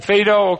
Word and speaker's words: Phaedo: He tied it in with I think it's called Phaedo, not F-I-D-O, but P-H-A-E-D-O, which I think is something about the, Phaedo, okay, the last, I use the Greek pronunciation.